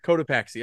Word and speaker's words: Cotopaxi: The Cotopaxi. – Cotopaxi.